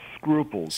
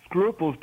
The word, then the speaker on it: Sgruples: Actually called Scruples. – Scruples, okay. – Scruples, because it's a play on the English word scruples.